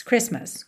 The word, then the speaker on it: Christmas: Ah, e lembra que Christmas, a gente não pronuncia a letra T no meio. Então, fica Chris, para no S, já vai para o M. Mas, Christmas, Christmas, ok?